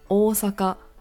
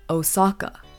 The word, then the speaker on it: Osaka: For the third city, we have Osaka. Now, a very common mispronunciation that I hear is Osaka, which is very Americanized. Understandable. But always remember that the O in Osaka is elongated.